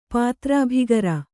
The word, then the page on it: ಪಾತ್ರಾಭಿಗಾರ: pātrābhigāra; Kannada